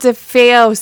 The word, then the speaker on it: DeFeo: DeFeo. – DeFeo. Junior. – DeFeo. You got to say it like a Guido.